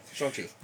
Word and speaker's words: Shang-Chi: Shang-Chi. We've been saying it wrong this entire time. The trailers have been clarifying it. – Shang-Chi.